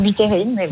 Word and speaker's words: Buterin: plutôt sur Buterin.